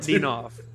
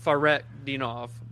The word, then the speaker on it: Fakhretdinov: Decay's silent. So, okay. Your H is silent. – Favret Dinov. – So it's really not that hard. You just take the K and the H out.